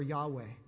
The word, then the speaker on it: Yahweh: It can be pronounced Jehovah or Yahweh.